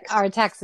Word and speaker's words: Artax: So if Morla is apathy and the Rockbiter is despair, and you know, Aatrax. I'm saying it wrong. It is. What's his name again? – Artax is sadness.